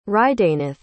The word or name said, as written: hridaynath